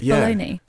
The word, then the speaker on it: bologna: They spell it like Bologna, but it's bologna.